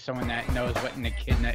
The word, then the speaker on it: Echidna: The E-C-H-I-D-N-A. How do you say that? – And she, I know, I remember we looked this up last time. – How do you pronounce? – Are we going to get to here? There we go. Yep. – Echidna? – Echidna. – Echidna. – Echidna. Oh, yeah.